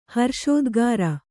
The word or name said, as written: ಹರ್ಷೋದ್ಗಾರ